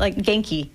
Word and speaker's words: genki: How do you pronounce that? – Genki. – Genki? – Genki. – Like with a G. – Hard G.